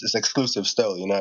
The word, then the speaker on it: XCLSV: I didn't even think of it that way, actually, because I that's another story for it, but that G Major Exclusive, I thought I was pronouncing it XCLSV.